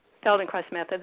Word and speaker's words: Feldenkrais: And as I understand, you're also a practitioner, I guess, a teacher of the, and help me say this correctly, the Feldenkrais method? – Feldenkrais method, that's correct. Yes.